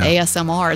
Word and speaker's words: ASMR: Oh, we'd have a lot of listeners because it's that ASMR.